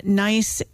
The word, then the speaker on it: gneiss: It is called There's Some Nice, as in G-N-E-I-S-S, Gneiss, Schist, and it's spelled S-C-H-I-S-T, and then in parentheses, don't take it for granted, instead of granted.